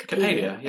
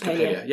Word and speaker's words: Coppelia: I hope I'm saying that correctly. Coppelia? – Coppelia, yeah. It's Coppelia. Yeah.